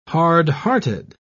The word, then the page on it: hardhearted: British English